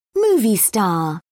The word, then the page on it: movie star: British English